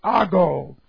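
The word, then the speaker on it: ago (ἄγω): We have the present, passive, indicative of the verb, the Greek verb, ago, A-G long O.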